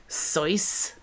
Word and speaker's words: Seuss: Have you guys heard that Dr. Seuss is actually pronounced like Soyce? – No.